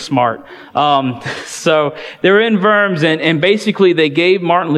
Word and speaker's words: Worms: It's spelled like worms, but they don't have W's, they use V's, so it's Worms.